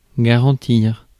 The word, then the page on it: garantir: France; /ɡa.ʁɑ̃.tiʁ/